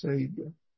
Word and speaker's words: Seigen: Sagan is Xingyuan in Chinese, but my Chinese pronunciation is terrible, so it's much easier to say it in Sino-Japanese, Sagan.